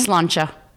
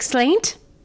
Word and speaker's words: Sláinte: Sláinte. – What's that? – Cheers. – Sláinte? Oh, yes. – Like slaint? Sláinte? – It's pronounced sláinte.